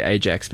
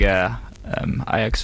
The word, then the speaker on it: Ajax: So the background of this is the two belligerents in this battle were the I'm going to say Ajax because my uncultured New Zealand upbringing would lead Ajax. – Me to do so. How do you say that again? – Ajax.